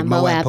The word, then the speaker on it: Moab: was Moab. Am I saying that right? – Moab police. Moab, Utah. Yeah.